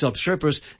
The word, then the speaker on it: obstreperous: So that's what it is. It's obstreperous, and obstreperous.